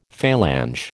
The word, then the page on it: Falange: American English